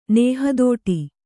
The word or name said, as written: ನೇಹದೋಟಿ